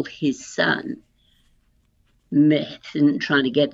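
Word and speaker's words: Myrddin: His name in Welsh is pronounced Methan, Merdan.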